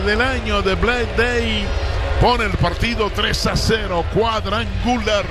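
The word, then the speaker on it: Bleday: Black Day. ¿Cómo se pronuncia? ¿Black Day? – Black Day. – Ok, Black Day.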